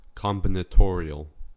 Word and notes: combinatorial: American English

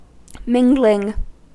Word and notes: mingling: American English

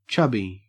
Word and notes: chubby: Australian English

